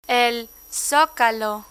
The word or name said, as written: el zocalo